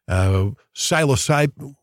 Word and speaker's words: psilocybin: Well, it's like the people who experience ayahuasca, or they have a psychedelic trip, or they use mushrooms, psilocybin, is that how you say it? – Yeah, that's it.